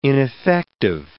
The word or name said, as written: ineffective